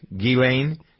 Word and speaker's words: Ghislaine: Ghislaine Max, Ghislaine? – Yes, that's silent, yes.